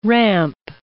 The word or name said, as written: ramp